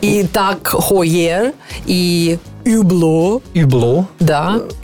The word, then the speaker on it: Hublot: Юбло.